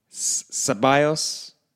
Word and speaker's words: Ceballos: Pepe, Ceballos.